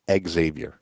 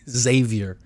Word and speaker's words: Xavier: It's not Xavier. – Xavier. – Thank you. You got to know that.